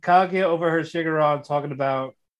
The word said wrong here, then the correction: Shirogane: Why are you saying Shirogane? – Yeah, it's his name. Shirogane. – Shirogane.